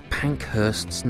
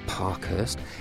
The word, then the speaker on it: Pankhurst's: Full disclosure, I mispronounced Emily or Emmeline Pankhurst's name. It's not Parkhurst.